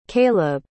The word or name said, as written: kaleb